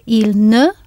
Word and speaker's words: il ne: Very often, in spoken French, the il and the ne are pronounced really close together, il, which means you can barely hear the ne.